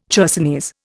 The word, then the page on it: chersonese: Indian